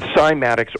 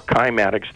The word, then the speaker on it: cymatics: Early experiments were done in cymatics or kymatics. The Greek pronunciation also allows you to say kymatics as well.